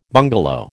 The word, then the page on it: bungalow: American English